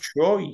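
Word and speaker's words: Troyes: It is pronounced Troy because they were talking about it on Football Weekly just to just today.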